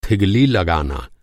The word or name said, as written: थिगली